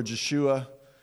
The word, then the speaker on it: Yeshua: You can see it written in Hebrew in the middle, and it's pronounced, well, it's even debated how it's pronounced, but Yeshua or Jeshua is the name of Jesus in Hebrew.